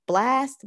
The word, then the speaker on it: Blxst: I think it's pronounced Blast, but it's BLXST, but I'm like, as soon as the theme song come on, I wanna live again time.